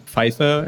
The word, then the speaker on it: Pfeiffer: Yeah, it's also it's fun having a German pronounce the name, because before, you know, we go around a couple of rounds of, you know, how do you pronounce Pfeiffer?